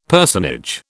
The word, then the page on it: personage: British English